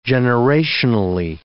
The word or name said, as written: generationally